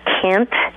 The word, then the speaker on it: Kind: Stump's book clarified that they lived in a village that was called Kent, but it's spelled K-I-N-D, but it's Kent.